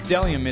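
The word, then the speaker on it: bdellium: Delium, it's a gum-like tree resin. It starts with a silent B.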